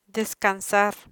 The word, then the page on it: descansar: Spanish